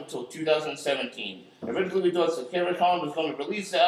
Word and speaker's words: Satyricon: Satiricron won't release a new album until next year, 2017. – Satiricon. – Satiricon.